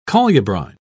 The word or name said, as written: colubrine